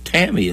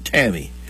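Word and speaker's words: Tamie: Rocky, and I guess she pronounces it Tammy. It's T-A-M-I-E. I would say that sounds more like Tammy, but probably calls it Tammy. Rocky and Tammy.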